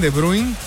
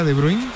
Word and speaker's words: De Bruyne: ¿Sabes cómo se dice en belga De Bruyne? – A ver. – De Bruyne. – De Bruyne. – De Bruyne. – Sí, nada que ver.